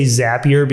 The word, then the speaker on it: Zapier: I always say Zapier because I think a lot of people say Zaps, not Zapes.